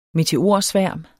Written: meteorsværm

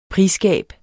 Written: prisgab

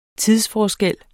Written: tidsforskel

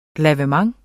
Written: lavement

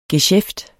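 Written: GESJÆFTER

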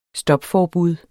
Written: stopforbud

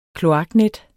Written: kloaknet

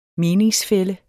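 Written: MENINGSFÆLLE

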